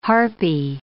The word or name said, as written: harpy